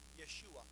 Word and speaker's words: Yeshua: Savior, in Hebrew, is pronounced Yeshua. It's translated as Jesus or Joshua.